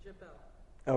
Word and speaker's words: Jebel: And the accent comes on the second syllable, so it's Jebel.